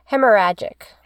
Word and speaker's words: Hemorrhagic: Hemorrhagic.